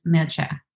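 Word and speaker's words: Mircea: It's M-I-R-C-E-A. I think it pronounced Mircha.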